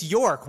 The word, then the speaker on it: York: It's York. Y-O-R-K. York. – Whatever. – Like New York, where you are.